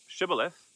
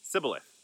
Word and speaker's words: Shibboleth: And there's another there's another tribe on the other side of the river, and one side says Shibboleth, and the other side says Sibboleth.